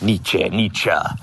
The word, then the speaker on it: Nietzsche: I never pronounce it quite right. It's Nietzsche.